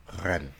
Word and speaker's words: Rennes: Rennes.